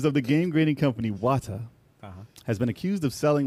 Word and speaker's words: Wata: You said it wrong, man. – Wata Guan. Book. – It's Wata.